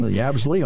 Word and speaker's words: Yabsley: So Yabsley. – Yabsley, all right. – I think I'm saying it right. Y-A-B-S-L-E-Y, Yabsley.